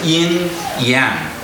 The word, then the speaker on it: yin yang: And then we have this yin yang. What is yin? Correct pronunciation here. Yin yang. Yin yang. Okay, yin yang.